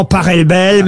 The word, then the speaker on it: Pachelbel: S'il est Allemand, ça se dit Pachelbel. – Exactement, ouais. Ça pourrait se dire éventuellement Pachelbel, mais on dit en tout cas, ici en France, le canon de Pachelbel.